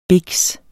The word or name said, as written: biks